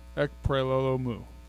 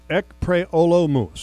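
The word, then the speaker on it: Ekpre-Olomu: Ekpre Olomu. – Ekpre Olomu.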